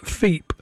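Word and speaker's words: FIEP: They're called F-I-E-P, I think that might be pronounced, Feep.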